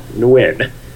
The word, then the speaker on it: Nguyen: It's as Elf said in the last questions thread, Nguyen. Of course, this would be considered the correct American way of pronouncing it, as the real Vietnamese way involves inflection slash tones that don't really exist in English, as far as I know. Anyway, and that's, of course, N-G-U-Y-E-N.